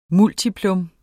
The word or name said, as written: multiplum